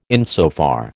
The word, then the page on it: insofar: American English